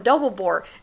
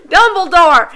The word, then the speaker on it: Dumbledore: Dumbledore.